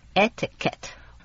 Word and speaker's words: etiquette: Etiquette 那个 Q U 是发 K 的音，而不是发 Q 的音。 Etiquette，我们来公司工作又不是来社交，讲什么礼节呀？ – Etiquette is very important to business。